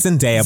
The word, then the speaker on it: Zendaya: I think it's Zendaya. – Zendaya. I know people get very mad if you say it wrong.